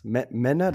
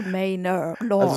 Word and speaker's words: Manor Lords: Wie spricht man es aus? Männer, das hat mir schon mal in der letzten Folge. – Wie Männer, sondern eher so wie M-A-N-O-R, also Manor Lords. – Also schreibt sich nicht M-A-N-N-E-R, Lords.